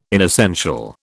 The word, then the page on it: inessential: British English